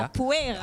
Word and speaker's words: Capoeira: Et puis un atelier de Caipoeira. Caipoeira, si je le prononce bien. – Capoeira. – Ah, Capoeira.